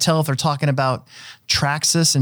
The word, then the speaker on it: Traxxas: Let's try to think up all the different mispronunciations that people mispronounce when we're talking about RC companies. You ready? Traxxas. – Trax. – T-Rex. Or T-Rax.